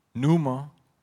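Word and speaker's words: pneuma (πνεῦμα): If we look at the meaning of spirit, pronounced pneuma,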